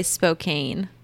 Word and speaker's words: Spokane: I was just in Spokane. – Good old Spokane. – Which I learned was Can, because it looks like Cane. And that's really unfair to phonetics. – Yeah, most people say Spokane.